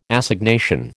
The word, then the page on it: Assignation: American English